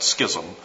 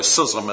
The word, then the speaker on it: schism: Now some of my Texas friends pronounce that schism, but really schism is the way to pronounce it, particularly in religious matters, a schism.